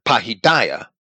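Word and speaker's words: pahidia (παιδεία): The Greek word is pahidia. Pahidia, it might be pronounced, but it's pahidia, which is primarily the whole training and education of children from the Greek word pahis, which is a child.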